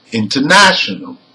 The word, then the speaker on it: international: Number one, international. Now, the primary stress is the third syllable from the end because it ends in A-L. Okay? But the secondary stress is on the prefix in.